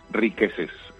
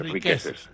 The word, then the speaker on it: Riquezes: Riqueces, R-I-Q-U-E-Z-E-S, Riqueces.